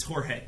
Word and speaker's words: Jorge: Garrig, Baden, Bedell, George, Aduro. – It's Jorge.